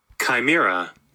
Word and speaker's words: Chimera: And tonight we're talking about an episode called Chimera. Is the correct pronunciation, I think? – Mm-hmm. – Chimera. – That's the one.